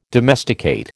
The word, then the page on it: domesticate: American English